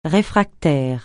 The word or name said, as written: réfractaire